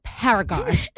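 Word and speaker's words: ParaGard: Today, we are going to do a live cleanse of Paramore. – No, Paraguard. Okay, I've been teaching her how to say this word. It's Paraguard. I mean, maybe I'm saying it wrong, but it's Paraguard.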